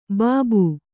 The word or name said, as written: ಬಾಬು